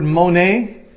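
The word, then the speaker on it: mone (μονή): It is the word mone. We would spell it in English characters, M-O-N-E. Of course, it's in Greek characters, but we would spell it, if you're taking notes, M-O-N-E, mone.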